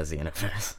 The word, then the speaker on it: Xenoverse: Is that the official pronunciation, Xenoverse? – Yeah, Xenoverse. – Okay, not Xenoverse, Xenoverse.